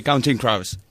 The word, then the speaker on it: Counting Crows: No, no, a ver, di Counting Crows.